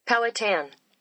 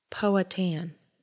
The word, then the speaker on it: Powhatan: Powhatan. – Powhatan.